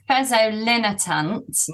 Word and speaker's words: fezolinetant: How do you pronounce that drug? Do you even know how to pronounce it? They called it Fezo for a while, and now the name is, what is the brand name? – It's Fezolinetant. – Linetant, thank you.